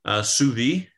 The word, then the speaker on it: sous vide: S-I-U-S, two words, V-I-D-E. – Oh, okay. So the second S is silent now. It's called sous vide. – Sous vide. Yes. And it's French. And so, Not Suba or Subaru, but sous vide.